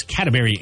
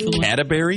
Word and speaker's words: Cadbury: Did you say Cadbury? – Yeah, that's Cadbury, not Cadbury. Yeah. Cadbury. No, I did say it. You're right. I said it wrong.